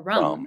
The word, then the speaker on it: ram: it's spelled ram, but it's pronounced more rum.